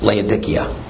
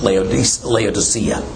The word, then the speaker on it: Laodicea: and that congregation is Laodicea, which is also properly pronounced Laodicea. Either way is fine.